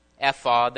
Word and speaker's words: ephod: We reviewed several things that happened and pointed out the role of the priestly ephod that was used to inquire of the Lord. I'm going to pronounce it that way.